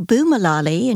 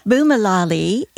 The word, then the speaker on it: Boomalali: So Boomalali, and you have to say it like that, Boomalali is a great little lifestyle company for those of us who are in the second half of life.